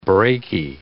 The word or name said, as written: braky